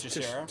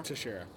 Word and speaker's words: Teixeira: Chris Teixeira. Teixeira.